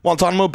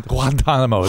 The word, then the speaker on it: Guantanamo: Oh, see, you got the Spanish, the Hispanic pronunciation because Tom speaks fluent. – Oh yeah, a little Guantanamo. It's like Guantanamo.